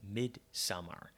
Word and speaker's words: Midsommar: And that would be the film, probably maybe the most controversial film of the summer, and that's Midsommar. And I say summer because I believe that's actually how it's pronounced, not Midsommar, which I've been saying for the last week or so.